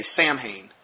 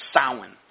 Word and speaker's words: Samhain: This is the season of it looks, if you just read the word, it looks like you'd say Samhain, but it's Samhain, is that how it's actually pronounced from an occult standpoint. It's spelled Samhain. It's pronounced Samhain.